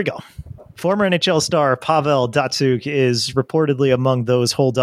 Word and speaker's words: Datsyuk: Datsyuk.